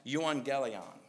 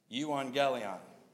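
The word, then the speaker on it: euangelion (εὐαγγέλιον): Now this word gospel Paul uses comes from a kind of a weird sounding Greek word called pronounced euangelion. So let's try to say that together. Ready? Euangelion. One more time. Euangelion.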